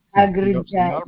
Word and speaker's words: Agrajaya: Agrijaya. By means of one elder sister. – Agrijaya. Agrijaya. – Agrajaya. – Pronouncing that wrong.